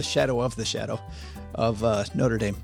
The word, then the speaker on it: Notre Dame: The one in Paris is Notre Dame. This is Notre Dame.